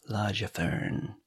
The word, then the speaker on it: Largefearn: It's called The Bard's Tale - Warlocks of Largefearn, and it's spelled L-A-R-G-E, like large, and then F-E-A-R-N, Largefearn.